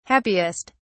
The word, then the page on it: happiest: British English